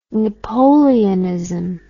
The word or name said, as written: napoleonism